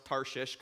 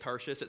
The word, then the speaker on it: Tarshish: And in case you're wondering, I did pronounce Tarshish correctly. It's one of those funny Bible words that doesn't sound right when you say it, Tarshish.